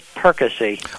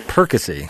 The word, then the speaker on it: Perkasie: Perkasie. – Oh, I'm sorry. – Well, it's a very common mispronunciation. – Perkasie.